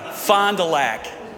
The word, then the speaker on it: Fond du Lac: Fond du Lac.